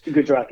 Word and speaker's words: Gujarat: Gujarat, yeah, yeah.